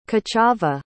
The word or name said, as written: kochava